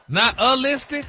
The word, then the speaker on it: nihilistic: Stop putting the ooh in there. – Nihilistic? – There you go.